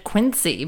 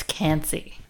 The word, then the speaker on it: Quincy: Not at all very far away, in fact, and they probably would also pronounce it Quincy, but it's in France where it's Cancy.